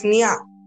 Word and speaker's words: Near: No, it's Nia. N-I-A. You pronounce it like that. His name is spelled like N-E-A-R.